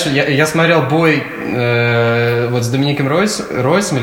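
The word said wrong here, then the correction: Reyes: или как его фамилия правильно произносится? Рейсом.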